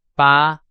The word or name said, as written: 把